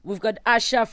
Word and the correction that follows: Asa: from Nigeria. – Yes. – Pronounce Asa.